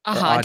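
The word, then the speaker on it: Aja: Aha, David Aha.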